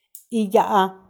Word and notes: iyaa: Macuiltianguis

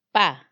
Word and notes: pa: Macuiltianguis

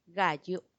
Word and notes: gayu: Macuiltianguis

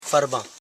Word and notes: far.ban: Koro

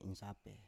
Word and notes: insape: Biak

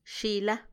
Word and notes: xila: Macuiltianguis

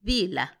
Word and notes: dila: Macuiltianguis

